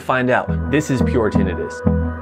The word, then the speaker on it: tinnitus: Hey, audiologist and tinnitus specialist in California. The correct way to pronounce the word is tinnitus. The other way to pronounce the ringing in your ears is tinnitus.